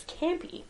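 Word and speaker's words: Kampê: So yeah, they break out of the Alcatraz cell, and they come across a hundred-handed one who's being tormented by the monstrous Campy. They help him escape, is that how you say it? – Yes.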